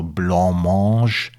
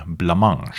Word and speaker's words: Blancmange: Ich selbst habe immer gedacht, der Name kommt aus dem Französischen, also muss er auch französisch ausgesprochen werden, also Blancmange. Nicht so die Protagonisten, die eben waschechte Briten sind. Die sprechen nämlich von Blancmange. Keinesfalls aber Blancmange, wie es vielleicht jemand aus Bayern intonieren würde.